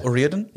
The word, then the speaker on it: O'Riordan: Spricht man sie so aus, O'Riordan? – Ja, habe ich nochmal nachgeschaut.